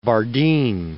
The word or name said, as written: Bardeen